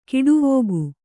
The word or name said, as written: ಖಡಾವಿಗೆ